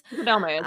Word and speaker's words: Fukudome: He was the first Cub to make their MLB debut as the opening day starter since Kosuke Fukudome in 2008. Mm-hmm. I'm pretty sure that I butchered that name. I apologize. – Fukudome is right.